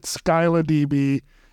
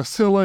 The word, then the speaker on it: Scylla: I've always pronounced it SkylaDB until I found out recently, Scylla is the more correct pronunciation.